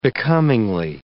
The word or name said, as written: becomingly